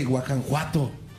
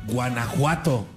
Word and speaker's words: Guanajuato: No se dice Guajanjuato, se dice Guanajuato.